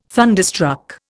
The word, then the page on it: thunderstruck: Indian